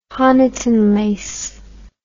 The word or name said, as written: Honiton lace